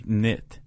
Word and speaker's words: cniht: There's a lot of letters in there that aren't being said, so. – C-N-I-H-T, knit.